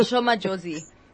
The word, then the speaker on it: Madjozi: Madjozi, is that how you say her name? Sorry. – Yeah, Shoma Josie.